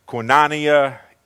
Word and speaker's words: koinonia: That Greek word is koinonia. I heard it pronounced koinonia. Either way, it's the Greek word for community.